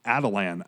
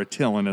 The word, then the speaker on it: Attilan: So of the Kings of Adelan, I guess is how they pronounce it. So, or Attilan, as I've always called it.